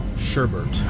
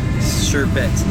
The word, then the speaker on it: sherbet: I would pronounce it sherbert. – Correct. – Why did you say? – Because there's no R at the end, like sherbet, not sherbert.